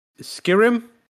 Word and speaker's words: Skyrim: It was a launch for a little indie game called Skyrim. Is that right? Skyrim? – Skyrim? Skyrim, yeah. – Skyrim? – I think the emphasis is on the rim part.